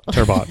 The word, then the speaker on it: turbot: It's not spelled like that. It has a T at the end. – Oh, turbo. – Yeah. Turbot, yeah.